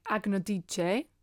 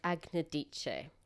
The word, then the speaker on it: Agnodice: Well, if we're going to make it sound a bit Italian, it's like Agnodice. – Dice, that's right. – Or Agnodice.